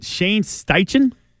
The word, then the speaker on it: Shane Steichen: Is it Shane Steichen? Did I say it right? – Yeah. – I think it is right.